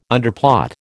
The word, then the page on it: underplot: American English